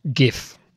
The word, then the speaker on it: GIF: It is GIF. – GIF. And GIFs are animated looping images, hard G pronunciation, as per one of the peanut butter jars. – And GIF, J-I-F, as in soft G pronunciation, is creamy peanut butter.